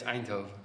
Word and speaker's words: Eindhoven: So we're in Eindhoven. Am I saying that right? Eindhoven? – Yeah. – I kept on saying Eidenhoven. – No, it's Eindhoven. – Eindhoven. Okay. Yeah.